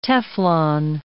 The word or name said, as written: Teflon